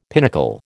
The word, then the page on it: pinnacle: American English